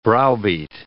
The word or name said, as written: browbeat